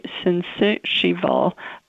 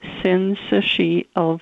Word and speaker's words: syncytial: RSV, or respiratory syncytial, oh, I didn't say that right. I'm sorry, Logan. – We'll cut it out. We'll cut it out. – Stab that again. Yeah. Respiratory syncytial virus is a respiratory illness.